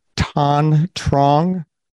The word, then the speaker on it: Tanh Truong: Today, we are here with Tanh Truong.